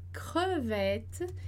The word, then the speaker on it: crevette: Então, observa lá que crevette, né? Creux, a gente não vai, o E, ele vai ser mudo pro primeiro, né? Então, creux. Vet, porque tem os dois T's ali. Então, o E anterior, a gente vai pronunciar com o som de E.